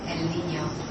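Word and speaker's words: El Niño: I'm not saying it right. – Huh? – It's not German. How do you say it? – El Nino. – El Nino. Okay.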